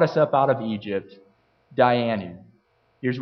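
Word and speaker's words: Dayenu: It's spelled in the English, it's D-A-Y-E-N-U, Dianu prayer.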